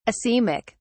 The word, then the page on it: asemic: British English